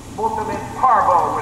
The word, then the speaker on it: multum in parvo: Multum in parvo would be our English pronunciation.